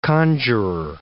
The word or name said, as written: conjurer